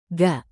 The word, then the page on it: gak: Indonesian